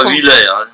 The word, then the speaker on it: Huillet: D'autant qu'il faut dire Huillet. – Huillet. – Ah, c'est un nom de Blois et là-bas. – Huillet comme l'huile.